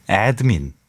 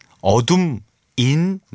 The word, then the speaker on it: ADMIN: 이게 제가 오프닝에 이제 어드민 나이트 이렇게 말씀드렸는데 이게 이제 한글로 나와 있잖아요. 에드민, 어둠 인 나이트가 아니고 에드민 나이트, 그러니까 에드민, ADMIN, 예, 그렇습니다. 어둠 인 나이트 아닙니다.